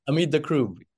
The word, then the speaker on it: Hameed Dakroub: Hamid Dakroub.